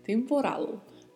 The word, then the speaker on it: temporal: Temporal. Mais uma particularidade aí que é a pronúncia do L, né? Não podemos esquecer, com a ponta da língua tocando a parte superior da boca.